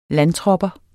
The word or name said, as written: landtropper